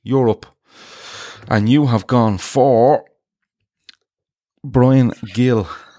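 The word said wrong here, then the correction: Gil: from Hill. – Hill. Gill, same thing. It's a G.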